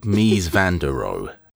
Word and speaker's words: Mies van der Rohe: Ludv- Ludwig Mies van der Rohe.